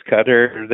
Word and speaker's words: Qatar: Qatar, the pronunciation is Qatar.